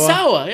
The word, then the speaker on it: Sawa: Sawa, is that how you pronounce it? Devon Sawa, yeah.